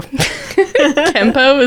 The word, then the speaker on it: Kenpo: I think I said that wrong. K-E-N-P-O. Kenpo, is that? – Kenpo.